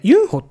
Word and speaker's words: ユンホ: あとは忘れちゃいけないのは子役のね、これユンホくんって言うんだって、ユンホって読むんだって。